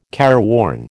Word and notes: careworn: American English

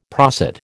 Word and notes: prosit: American English

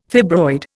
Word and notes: fibroid: Indian

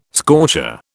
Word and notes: scorcher: British English

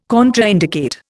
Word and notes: contraindicate: Indian

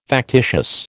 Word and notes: factitious: American English

